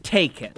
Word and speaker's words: Takken: Okay, it is a video game spinoff of the Taken franchise, but it's spelled T-A-K-K-E-N, Taken. – Like Tekken.